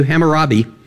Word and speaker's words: Hammurabi: Hammurabi? Thank you. Hammurabi.